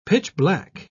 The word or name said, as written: pitch-black